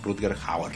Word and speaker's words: Rutger Hauer: Ah, Ruten, Rutger, Rutger. Rutger Hauer.